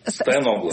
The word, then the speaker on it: Стояноглу: Ну вот вам придется отвечать сразу за две позиции тех, кто живет в Москве и голосует за генерального прокурора бывшего Александра Стояноглу и за тех, кто... – Стояногла. – Стояногла все-таки, да, простите, пожалуйста, Стояногла, буду правильно говорить.